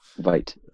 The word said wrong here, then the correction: Vite: So I have a good authority for Michael Bourne, but it's pronounced Veet.